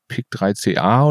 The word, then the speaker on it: PIK3CA: Aber jetzt zum Beispiel, oftmals liest man jetzt auch dieses PIK3CA oder so.